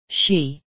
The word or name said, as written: she